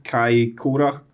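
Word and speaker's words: Kaikōura: To Kaikoura. – Good pronunciation. – Is it Kaikoura? – He said that well.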